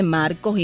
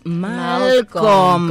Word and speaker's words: Malcolm: Y todo el mundo le dice Marcos y yo le digo es Malcolm. – Como Malcolm X. – Sí, así mismo, y tengo que poner la lengua.